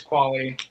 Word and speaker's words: Qualley: Did I pronounce that right? I don't know. I apologize. – It's Qualley.